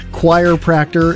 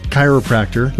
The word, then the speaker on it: chiropractor: And then other people said choir practor instead of chiropractor.